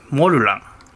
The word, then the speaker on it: モルラン: アイヌ語でモルランイントネーションは違うんだけどね、